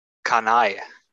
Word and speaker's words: Kani: den gleichen Ilmatic, Sir Benny Miles, Karl, wie wird es ausgesprochen? – Kanai. Karl Kanai, zum Glück habe ich die Kurve noch schnell gekriegt.